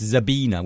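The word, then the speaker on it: Sabina: I just read this article that said that she's German and it's Zabina with a Z and it's Litsicki because it's obviously a Polish name in origin or something like that.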